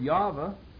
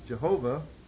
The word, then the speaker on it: Yahweh: The Lord, which is the word Yahweh, often mispronounced Jehovah, and that's a sort of very special name that belongs to the Lord.